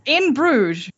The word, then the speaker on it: In Bruges: In Bruges. – Yeah. – In Bruges.